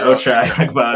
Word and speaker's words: Ochai Agbaji: Yeah, so I think that they're pretty sure in how they feel about Lauri Markkanen, Walker Kessler, and probably Okbai Agbaji. Sorry, I definitely butchered that name. – But Ochai, yeah. – Surprised. Yeah, Ochai Agbaji.